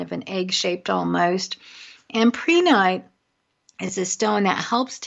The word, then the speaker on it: prehnite: And this crystal is called prehnite, and it's spelled P-R-E-H-N-I-T-E. Some people call it prehnite. I refer to it as prehnite. I've heard it said both ways.